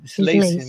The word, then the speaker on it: slice: Slice, não sei como é que se pronuncia. – Slice, né? Isso mesmo.